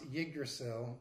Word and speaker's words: Yggdrasil: It's a rather strange spelling. It's Yggdrasil, but we looked it up on Merriam-Webster's, and apparently it's pronounced Yggdrasil, you know what I mean.